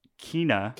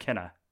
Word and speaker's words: Kena: Kena. – Kena: Bridge of Spirits. – Bridge of Spirits. Okay. Spelled like Kena. K-E-N-A.